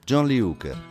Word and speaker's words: John Lee Hooker: Comunque, John Lee Hooker si pronuncia sempre così, tutto assieme, mi raccomando.